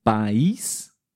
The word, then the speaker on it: país: exemplo, país, a sílaba forte na última, né?